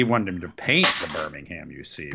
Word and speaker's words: Birmingham: Birmingham. Birmingham. It's Birmingham here. Over in England, it's Birmingham.